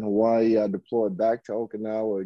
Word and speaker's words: Okinawa: After Okinawa, I'm not even saying that right, am I? Okinawa? – Yeah, okay. Okinawa.